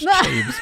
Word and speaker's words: Chaves: I put Mike Chavez. – I think you're fine if the man you're speaking of is who I think it is. It's pronounced Chaves, but all right.